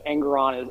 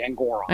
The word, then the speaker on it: Engoron: Like, honestly, I actually have a feeling that what Engoron is, I always mispronounce his name. It's actually Engoron.